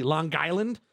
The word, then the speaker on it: Long Island: So you got to say it with the hard G. Long Island.